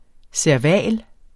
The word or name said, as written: serval